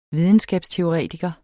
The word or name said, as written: videnskabsteoretiker